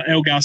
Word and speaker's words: Elgar: Um, Dean Agar, uh, Elgar, sorry.